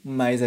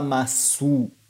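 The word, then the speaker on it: Mas (ます): Tá vendo que aqui em cima também a gente escreveu SU, mas a gente não vai falar masu ou maço. É mas. Mais uma vez. Mas. Entendeu? Então a gente não vai pronunciar esse U no final de mas e des.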